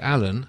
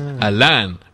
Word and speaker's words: Alan: His is Alan, with the emphasis on the Lan. Yours is Al-An, with no real emphasis on either syllable.